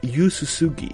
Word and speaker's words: Yu Suzuki: Así lo dijeron principalmente por esta, este esta saga creada por Yu Yuzuki, ah, por Yuzuki, sí, Yuzuki, ahí está, aprende a hablar mono aprende a pronunciar bien el japonés. Yu Suzuki, ahí está.